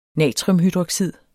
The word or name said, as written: natriumhydroxid